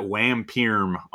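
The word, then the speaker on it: WarmPerm: Warm Perm, who you might know is at Wampirm on Twitter. Did I say that right? Is that how you like that? – Yeah, Wampirm. Yeah, that's right.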